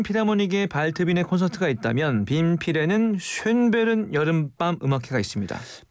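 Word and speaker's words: Schönbrunn: 신년음악회가 비싼 티켓을 구입해 턱시도와 드레스를 입고 듣는 음악회라면 쉔베른 여름밤 음악회는 일반 대중을 대상으로 하는 무료 음악회입니다. – 전 쉰부터 갈 수 있는 줄 알고. – 이게 발음이 어렵습니다. 쉰부른인데 쉰이 될 수도 있어요. 네, 쉰부른. 네.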